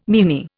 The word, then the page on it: meanie: Indian